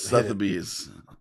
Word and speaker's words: Sotheby's: There is a Google's pronunciation for Sotheby's, and it is Sotheby's.